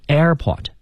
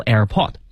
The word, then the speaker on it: airport: 这个重音啊，我呢给大家查了一下，就是通常情况下它也有一些变化啊，就通常情况下名词的话呢，重音要放在开头。 – 嗯啊，所以比如你以前学个词叫产品，叫 product，对吧？所以这词呢我们就可以读成 airport，你要读 airport，这就有点问题了啊。